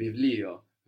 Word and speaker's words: βιβλίο: It's written with the vita that looks like a P, but we pronounce a V there, no? Mm-hmm. Vivlio.